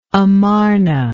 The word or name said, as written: Amarna